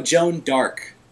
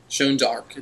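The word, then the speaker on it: Jeanne d'Arc: No, Joan d'Arc. – Joan d'Arc. It's, you know, it's the French pronunciation.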